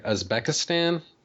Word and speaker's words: Uzbekistan: Uzbekistan? – Yes.